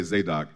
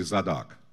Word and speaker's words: Zadok: Some Americans say Zadok. The Eastern way would be Zadok, right?